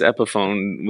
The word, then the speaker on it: Epiphone: Epiphany. Epiphone. – I said epiphany. – Epiphany. Like, yeah. It's just Epiphone with the emphasis on the different syllable there.